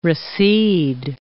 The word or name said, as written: recede